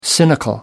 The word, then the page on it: cynical: American English